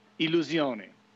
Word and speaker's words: Illusione: Yeah, so the proper way to say it, I think, in its Italian form, is Illusione.